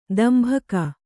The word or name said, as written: ತಂಬಾಕು